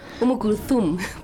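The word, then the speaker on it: Umm Kulthum (أم كلثوم): Volvemos de la pausa, escuchábamos un fragmento de una artista maravillosa árabe, Umm Kulthum. ¿Lo dije bien? – Umm Kulthum, pero sí, ahí estoy.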